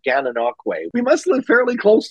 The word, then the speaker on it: Gananoque: He lives with his spouse in Gananoque, Ontario, Canada, where he enjoys camping and boating in the Thousand Islands.